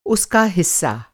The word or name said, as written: हिस्सा